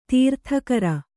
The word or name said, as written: ತೀರ್ಥಕರ